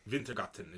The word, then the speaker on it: Wintergatan: Wintergatan is how he pronounces it.